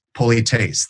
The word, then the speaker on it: polites: Polites, that's how they would describe it. So that's polites with a long A.